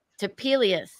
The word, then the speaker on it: Topelius: Tapelius.